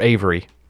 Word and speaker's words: Every: The case of Henry Every, or Avery. So much easier to say Avery. When you say Every, you're like, Every what? Yeah, it was annoying. But I said Every because that's exactly how it's spelled, like the common spelling of Every.